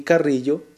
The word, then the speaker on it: Carrillo: Bejarano se escribe con B de burro, B-E-J-A-R-A-N-O. Y Carrillo, C-A-R-R-I-L-L-O.